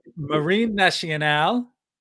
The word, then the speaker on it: Marine Nationale: Marine Nationale, right? Is that how you pronounce it? – Yeah.